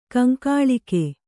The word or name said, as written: ಕಂಕಾಳಿಕೆ